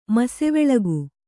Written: ಮಸೆವೆಳಗು

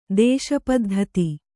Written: ದೇಶಪದ್ಧತಿ

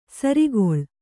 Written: ಸೂರುಗಲ್ಲು

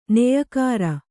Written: ನೆಯಿಕಾರ

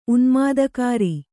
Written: ಉನ್ಮಾದಕಾರಿ

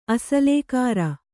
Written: ಅಸಲೇಕಾರ

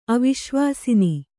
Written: ಅವಿಶ್ವಾಸಿನಿ

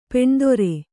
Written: ಪೀನೋದರ

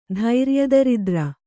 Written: ಧೈರ್ಯದರಿದ್ರೆ

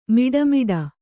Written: ಮೋಡಾಮೋಡಿ

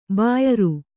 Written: ಬ್ಯಾರ

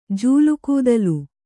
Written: ಜೂಲುಗೂದಲು

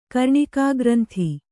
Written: ಕರ್ಣಿಕಾಗ್ರಂಥಿ

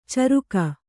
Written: ಚರಿಗೆ

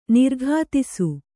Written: ನಿರುಕ್ತಿಸು

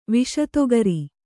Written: ವಿಷತೊಗರಿ

